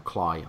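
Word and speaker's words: Cley: Clay, I think it's pronounced actually, but spelled like Clay. – Clay. – With an E, not an A.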